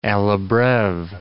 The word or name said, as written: alla breve